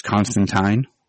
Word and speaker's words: Constantine: Yeah, and it was interesting because also they went with the British, I guess, proper pronunciation, where it's Constantine.